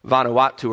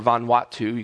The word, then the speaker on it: Vanuatu: Patton was a missionary to the New Hebrides, now called Vanuatu or Vanuatu. You can pronounce it either way in the 1800s.